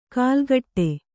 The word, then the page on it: ಕಾಲ್ಗಟ್ಟೆ: kālgaṭṭe; kannada